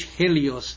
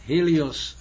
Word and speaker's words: Helios: You see, the H, Helios, the H is not pronounced in Greek.